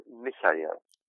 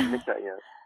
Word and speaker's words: Michael: it spells like Michael, but it's pronounced Michael. – Michael. – Yeah, very good. Yeah, Michael. It is this. I grew up in Germany, so it's the German way of saying Michael, but it's also just a Hebrew way of saying Michael.